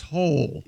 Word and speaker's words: Hole: But it's pronounced whole. – Yeah. – Hole? – Detective A. Hole. Yeah. – It's spelled H-O-L-E, Detective Hole.